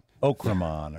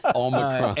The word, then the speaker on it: Omicron: Okramon or whatever. – Okramon. Thank you very much. No. Okramon. – Omicron.